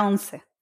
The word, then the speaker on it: once: And then there's a fifth chapter, and am I pronouncing it right? Is it once? – Yeah, la once. Yes.